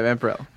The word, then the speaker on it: Vampirella: How do you say it? Vampirella? – I think so. – Vampirella? That's how you say her name, right? – Yeah, Vampirella.